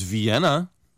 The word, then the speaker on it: Vienna: Well, they mentioned, of course, Vienna. – 네. – And London. – 맞습니다. 우리가 비엔나라고 하잖아요. 근데 영어식 발음은 N 발음이 겹치면 자음이 겹치면 한 번만 발음을 해요. 그래서 비엔나가 돼요.